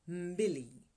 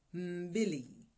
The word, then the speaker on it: mbili: Snowy Kilimanjaro is the highest mountain in Africa two is pronounced mbili, mbili.